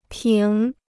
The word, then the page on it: 平: píng